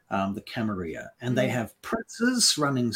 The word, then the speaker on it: Camarilla: Sometimes called the Camarilla, depending on who you ask, but properly pronounced Camarilla.